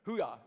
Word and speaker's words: Hooah: All right, that's the right pronunciation. I wasn't sure. Okay, good. Hooah.